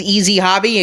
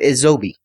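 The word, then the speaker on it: EzHobi: He was very, very clear, because I said, oh, this is Easy Hobby. He goes, when you're speaking it, pronounce it Edzobi.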